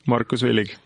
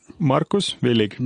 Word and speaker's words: Markus Villig: I hope I pronounced it correctly. – That's good enough. – you pronounce it for us, please. – Markus Villig. – Wow. Okay, that's a little bit different. One more time, just for our tongues, Markus Villig.